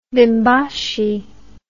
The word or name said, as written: bimbashi